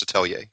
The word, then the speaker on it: atelier: But the point is, it's pronounced atelier.